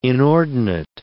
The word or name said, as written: inordinate